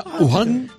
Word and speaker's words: Wuhan: Se pronuncia Wuhan, porque Wuhan, la W no se pronuncia en chino.